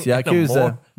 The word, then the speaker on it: Yakuza: Also, Yuzakuya, is that how you say it? – No, meets Animal Crossing. – No, Yakuza.